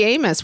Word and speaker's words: Amos: So everybody's favorite Tori Amos, which rhymes with virus.